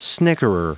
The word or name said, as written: snickerer